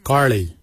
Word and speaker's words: Giilyi: Darp is spelled D-H-A-A-B. It is a traditional knife, a darp. For boomerang, we say karli. Karli is the traditional name for boomerang. Karli is spelled G-I-I-L-Y-I, karli.